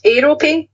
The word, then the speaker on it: Aerope: It's spelled Aero as in the chocolate bar. A-E-R-O and then P-E. How would you pronounce that? Aerope? – Aerope, yeah, that's nice.